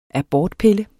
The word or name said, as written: abortpille